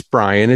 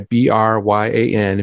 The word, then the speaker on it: Bryan: Um, so if you text my name, it's Bryan. It's I spell it with a Y, B R Y A N.